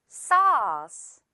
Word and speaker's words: sauce: 우리가 외래어로 이제 말을 할 때는 소스 이렇게 표기도 하고 말도 하다 보니까 영어로도 소스 약간 이렇게 발음할 수도 있는데 그게 아니라 우리 이 AU 발음 어떻게 한다고요? 어 발음. 그래서 sauce, sauce가 되는 거죠.